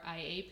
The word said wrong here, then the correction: AI: No, AI. I keep telling her, I keep saying it wrong. That's why I don't shorten it. I just say artificial intelligence because I know I'm going to say it wrong.